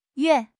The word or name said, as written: yue4